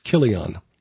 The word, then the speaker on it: Chilion: So, clearly a woman named for beauty, whether she, any, the names of his two sons were Mahlon and Chilion, spelled with a CH, but that would be a hard K sound.